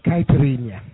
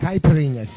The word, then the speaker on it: caipirinha: It's caipirinha. – Caipirinha? – Caipirinha.